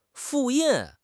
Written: fu4 yin4

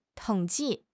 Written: 统计

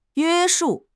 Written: 约束

